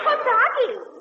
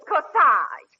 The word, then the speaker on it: corsage: Kasagi? Oh, Parky, darling, it isn't Kasagi. It's Corsage. A-G-E is pronounced Aj, as in garage.